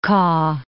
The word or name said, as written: Kaw